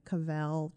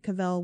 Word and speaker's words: Cavell: Cavell? Cavell? I looked up the pronunciation in a d- couple different spots and I heard different things, but I'm going to go with Cavell just because it, it's I'm probably going to default to that either way.